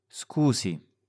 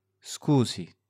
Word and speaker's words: scusi: Scusi. Scusi.